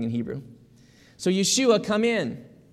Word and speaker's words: Yeshua: And Jesus is a shortened form of that, and it's called Yeshua.